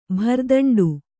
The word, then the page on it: ಭರದಂಡು: bharadaṇḍu